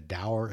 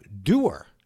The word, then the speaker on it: dour: But anyway, in the Jack Reacher books, that word always came up, doer, and that is not how I would have pronounced it. Over the years, I'd hear it pronounced doer, I'd hear it pronounced dour. One day I went to Google, I searched, they brought the word up. That little, you know, speaker icon you could click to hear the word pronounced, and they pronounced it doer.